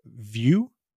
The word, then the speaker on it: Vue: And it's about combining Flask with the JavaScript framework Vue, which I'm pretty sure that's how it's pronounced, yeah. though I've heard it pronounced Vue. Oh. But I'm pretty sure it's intended to be like a view of something.